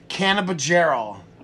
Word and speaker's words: cannabigerol: They consider this a minor cannabinoid, and it's pronounced cannabigerol.